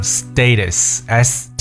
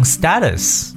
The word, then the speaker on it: status: 让我们看一下这个状态，叫 status，s t a t u s，status。当然，在这个美国英语当中呢，也有可能把它读成 status 这样一种读法。